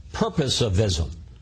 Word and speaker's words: purposivism: purposivism. Did I pronounce that correctly? – Yeah, you did.